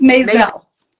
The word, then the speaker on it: Mazel: It's Mazel.